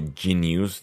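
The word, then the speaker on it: jeen-yuhs: We got a first look at Jin Yu, J-E-E-N-U-H-S. Y-U-H-S. A document on Kanye West. So, genius, that's how it's pronounced.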